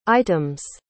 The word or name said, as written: item's